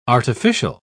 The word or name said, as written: artificial